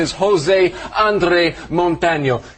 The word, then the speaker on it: José: Josie. André Montano. – No. Not Josie. – But it looks like Josie. – I know it looks like Josie, but in Spanish, the J is like a huh.